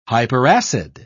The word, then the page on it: hyperacid: British English